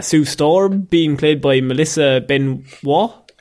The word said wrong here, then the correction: Benoist: I think it's actually Melissa Benoist. Benoist, okay. – But everyone tries to make it too fancy.